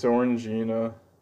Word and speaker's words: Orangina: It's Orangina. – Okay. You always pronounced it like vagina, so Did I? – yeah, you did.